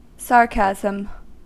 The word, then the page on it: istihza: turkiska